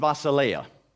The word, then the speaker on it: basileia: The Greek word for kingdom is basileia. Did I get the accent right? Basileia.